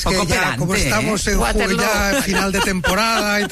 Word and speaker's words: Waterloo: Waterloo se pronuncia igual que Feijóo.